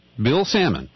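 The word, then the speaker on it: Sammon: And here is an interview with this unbiased journalist who is, it rhymes with Gannon, Bill Salmon, okay?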